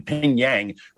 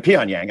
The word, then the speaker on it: Pyongyang: And then obviously we saw what happened with social media suppressing and censoring the Hunter Biden story like we're living in Pyongyang, right? Pyongyang, I should pronounce that correctly.